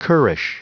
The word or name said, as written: currish